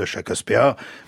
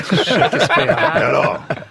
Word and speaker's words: Shakespeare: Bien sûr, il y a eu Othello de Shakespeare, mais surtout... – Shakespeare. – Alors, c'est comme ça que ça se prononce chez nous. – C'est comme ça que ça s'écrit, surtout.